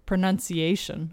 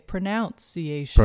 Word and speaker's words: pronunciation: One of the words is actually, even when we talk about pronunciation, a lot of people say pronunciation. – Pronunciation. – Or they spell it that way as well. They'll put the O in the same way you would say pronounce, but when you say pronunciation.